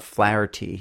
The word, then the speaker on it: Flaherty: Rob Flaherty. He's got an H in the middle. Flaherty. Rob Flaherty's plugins.